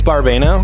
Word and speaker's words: Barbano: I stopped correcting people a long time ago. It's Barbano.